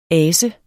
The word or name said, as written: ase